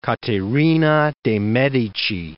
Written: Caterina de' Medici

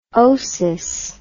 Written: osis

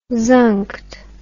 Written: zunked

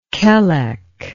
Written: Kelek

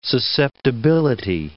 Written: susceptibility

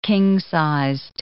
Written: king-sized

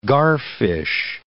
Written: garfish

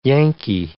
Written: Yankee

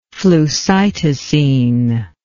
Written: flucytosine

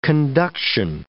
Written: conduction

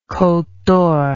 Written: Cote d'Or

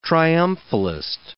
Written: triumphalist

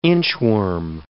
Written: inchworm

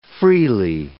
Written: freely

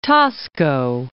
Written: Taxco

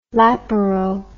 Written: laparo

